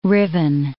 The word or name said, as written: riven